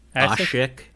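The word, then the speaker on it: Aşık: But Asik, is it, how do you say it? Is it Asik? – Asik.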